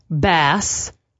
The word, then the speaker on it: Bass: Eric Bass. Unfortunately, yes. – You don't pronounce it bass, as the bass player.